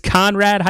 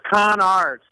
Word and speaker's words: Conard: It's Conrad High School. Is that how you say it? – It's Conard, C-A-R-D.